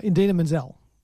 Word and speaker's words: Idina Menzel: Idina Menzel.